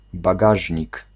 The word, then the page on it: trunk: American English; [t(ʃ)ɹʌŋk]